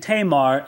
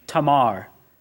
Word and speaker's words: Tamar: You have his sister, who must have been a beautiful young woman, says the lovely sister, whose name was, I've been saying Tamar, and I probably will. It's undoubtedly an incorrect pronunciation. You'd probably say her name Tamar. A's are always pronounced, never A in most other languages, but ah. And so undoubtedly it would be Tamar, but forgive me, I'm just in the habit of saying Tamar, so I'm sure I'll say that mostly tonight.